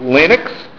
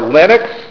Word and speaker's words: Linux: And that refers to Linux, and you'll see it pronounced Linux or Linux, named after Linus Torvalds, who was the initial developer of it.